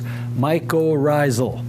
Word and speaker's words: mycorrhizal: This is how you pronounce the words, mycorrhizal.